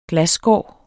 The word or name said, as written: glasskår